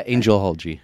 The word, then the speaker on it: angelology: Rumor has it, and I've heard that you've done some studies in geology. Is that how you pronounce it? – Yeah, angelology. – Angeology? – Angelology. – Angelology. Yeah.